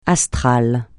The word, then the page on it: astral: francais